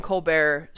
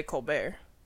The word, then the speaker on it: Colbert: It's fine, but it's like Stephen Colbert stopped pronouncing the T at some point.